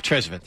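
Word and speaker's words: Tresvant: Trezvant, or how do you pronounce it? – Ralph Trezvant.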